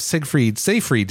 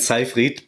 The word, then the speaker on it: Seyfried: On Sunday, we saw the reveal of Dungeons of Hinterberg, and joining us today are Regina Riesinger and Philip Seyfried. Did I get those? I totally butchered that, didn't I, guys? – It's Riesinger and Seyfried, but okay.